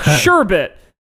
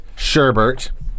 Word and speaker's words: sherbet: Sherbert. I do pronounce it sherbert, which is weird because that's not how it's spelled.